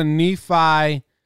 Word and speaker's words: Nephi: Let's go to Nephi, Utah, which is how everyone's telling me it's pronounced.